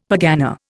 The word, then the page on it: pergana: Indian